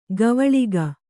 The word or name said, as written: ಗವಳಿಗ